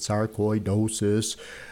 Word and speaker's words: sarcoidosis: And it explains, it says how to pronounce it, sarcoidosis, an inflammatory disease characterized by the formation of granulomas, yada, yada, tiny clumps of inflammatory cells, and it goes on.